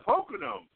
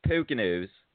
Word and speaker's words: Poconos: Yeah, and the Poconos, right? – I believe it's pronounced Poconos. Poconos.